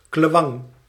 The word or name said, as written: sword